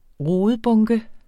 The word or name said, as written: rodebunke